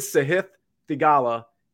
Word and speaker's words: Sahith Theegala: Everybody pronounces his name wrong. Literally everybody. Even when they pronounce it right, they'll say it again and they'll pronounce it wrong. His name is Sahith Theegala and he's 8,800.